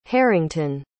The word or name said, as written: harrington